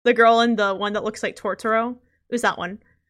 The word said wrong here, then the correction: Totoro: You mean Totoro? – Yeah, I don't know how to say his name. – What? Yeah, Totoro.